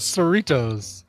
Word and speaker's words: Cerritos: How do you say it? Cerritos. – Cerritos.